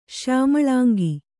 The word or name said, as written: ಶ್ಯಾಮಳಾಂಗಿ